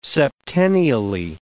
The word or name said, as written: septennially